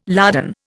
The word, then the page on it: lardon: Indian